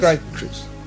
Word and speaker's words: Time Crisis: Time Crisis.